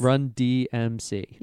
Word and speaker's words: Run DMC: Run DMC.